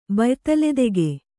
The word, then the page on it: ಬಯ್ತಲೆದೆಗೆ: kannada